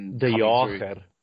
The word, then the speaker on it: de Jager: And they've got another young kid called Lude de Jager. I don't know if the pronunciation is right on that. I'm probably almost certainly not. – De Jager. – De Jager. Okay, right.